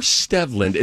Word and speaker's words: Stevland: It's Steveland. – Hmm. – Or Stevland. It's spelled Stevland.